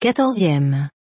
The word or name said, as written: 14eme